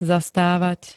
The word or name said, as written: zastávať